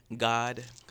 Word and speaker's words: Gawd: Well, my name is Bead Gawd because I beads on my neck. – I see. – The God is spelled G-A-W-D, and it stands for greatness attached with dreams.